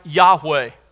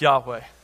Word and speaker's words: Yahweh: It was probably pronounced something like Yahweh. We can't be absolutely certain because in the original Hebrew text there were no vowels, only consonants. But probably something like Yahweh.